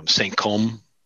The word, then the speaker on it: Saint Cosme: Saint Côme, right? – Which is spelled Saint Cosme.